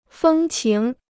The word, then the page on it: 风情: fēng qíng